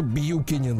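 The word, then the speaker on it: Buchanan: А в 1791 Джеймс Бьюкинен пишется Буханан. А читается почему-то Бьюкинин.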